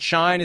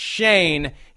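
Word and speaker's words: Schoen: Is it Shine? Is it Shone? Is it Shoon? It's Shane.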